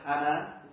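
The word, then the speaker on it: Hana (חנן): Well, the root word for grace in Hebrew is spelt C-H-A-N-A-N. Now I would, in my Cockney rhyming language, say Chana. But it's probably not pronounced that way. Is that right, Mosh? It's not pronounced. It's kind of Hana.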